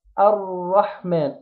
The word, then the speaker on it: الرحمن: طيب نجيب بقى كلمة الرحمن وهنبدأ بالآية، فنقول الرحمن نظر الايه همزة الوصل، لكن لو وصلناها بما قبلها او وصلنا ما قبلها بها همزة الوصل لا تنطق، فنقول الرحمن، الراء مفخمة مشددة مفتوحة، الراء الراء الرحمن،